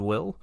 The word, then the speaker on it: Wyl: The other character that has been kind of focusing on is a character named Will. It's spelled W-Y-L, and he is an A-Wing pilot.